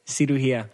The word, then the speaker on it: cirugía: I say surugia. Cirugia. Cirugia. – Oh, you say it wrong too, and I still got it. – It's surgery. Yeah. – Ooh. – Surgery. Cirugía. And if you eat better, you'll have less surgeries.